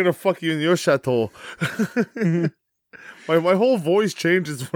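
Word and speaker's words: château: Yes, Chateau.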